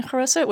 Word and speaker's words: haroset: And mine is a more traditional Eastern European haroset, which is just apples, walnuts, wine, and I use nutmeg. But some people use cinnamon, but I like to use a little nutmeg. – Haroset is how it's pronounced, and it is a Hebrew word meaning clay.